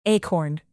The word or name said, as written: acorn